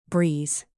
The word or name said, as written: breeze